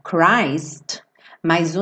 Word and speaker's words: Christmas: Só lembrando essa pronúncia do Christmas, que embora Cristo, ou seja, se você tirar o M e a S, você vai pronunciar Christ, mas o Natal é Christmas, tá bom? Não é Christmas.